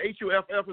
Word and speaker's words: Huff: And Huffster, the HUFF is the actual pronunciation of my name.